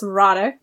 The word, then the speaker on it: Roddick: So also, it's Roddick. They pronounce the R's. – Mm, the Scottish accent? – Yes. Sometimes they have a different R that's almost rolled.